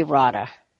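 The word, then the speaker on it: Rodda: I guess it would be Rada. Yeah, you're probably right. It's Rada. Because it would be a short O because of the two Ds.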